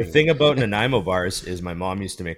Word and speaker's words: Nanaimo: Nanaimo. I said that. Did I say that right? Nanaimo? – Nanaimo Bars. – And Nanaimo Bars.